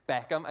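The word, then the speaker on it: Bauckham: So Richard Beckham, I know it says Borkham, but apparently pronounce it Beckham.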